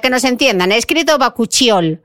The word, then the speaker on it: bakuchiol: bakuchiol. ¿Lo he dicho mal o lo he dicho bien? – Bakuchiol. Ahí tenemos una montada, dice la doctora María Jesús Lucero, que es bakuchiol.